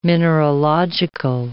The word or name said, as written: mineralogical